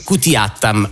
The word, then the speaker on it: Kutiyattam: È abbastanza semplice perché si pronuncia come si legge, cioè teatro Kutiyattam, ok.